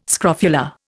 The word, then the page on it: scrofula: Indian